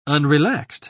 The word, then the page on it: unrelaxed: British English